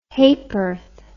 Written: haporth

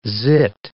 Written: zit

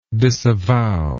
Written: disavower

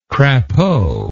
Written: crapaud

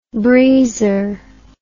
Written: breezer

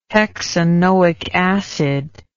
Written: hexanoic acid

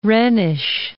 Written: Rhenish

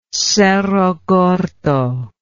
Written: cerro gordo

